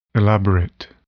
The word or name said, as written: elaborate